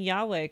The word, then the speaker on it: Yahweh: I just realized I don't actually know if I'm pronouncing Yahweh correctly or not. – That's correct. That is correct.